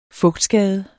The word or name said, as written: fugtskade